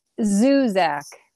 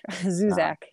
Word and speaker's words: Zuzak: Zuzak. Yeah, like Zuzak. – Zoo and Zach. Zuzak.